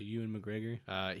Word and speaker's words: Ewan McGregor: Yeah, Ewan McGregor.